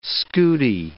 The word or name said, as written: scudi